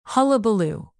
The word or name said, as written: hullabaloo